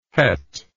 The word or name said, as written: cheth